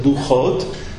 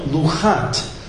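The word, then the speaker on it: Luchot (לחת): It's red Luchot, but it's spelled Luchat, as if it's one tablet, one piece of stone.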